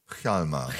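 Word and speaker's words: Hjalmar: Chalmar Söderberg. – Chalmer. – Chalmer, habe ich wieder falsch ausgesprochen.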